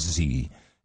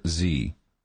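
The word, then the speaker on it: Xe: Hey, man, just pronounce it like Z. You know, like Q. – And it's X-E? – Capital X under lowercase e. But it says the parent's company new name is pronounced like the letter Z.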